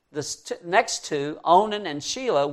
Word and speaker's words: Shelah: Now, Shelah is spelt with I and I. It's S-H-E-L-A-H. And so it's Shelah.